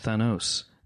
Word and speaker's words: Thanos: Thanos. Is the way that it is being pronounced.